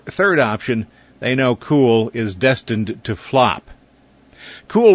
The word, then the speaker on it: Cuil: They call it Cool, C-U-I-L, pronounced cool. They say it's an old Irish word for knowledge.